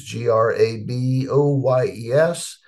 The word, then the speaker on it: Graboyes: it's G R A B O Y E S, and type Graboyes and channel, and up my channel will come in on YouTube.